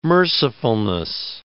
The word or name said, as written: mercifulness